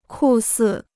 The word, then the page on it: 酷似: kù sì